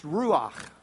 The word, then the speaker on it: ruach: The Hebrew word for spirit is pronounced ruach.